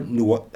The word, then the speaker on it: Noé: My second movie is Gaspar Noé. How do you say his name? Gaspar Noé.